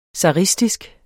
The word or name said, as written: zaristisk